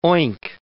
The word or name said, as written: oink